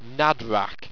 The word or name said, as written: Nadwrrak